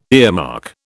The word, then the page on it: earmark: British English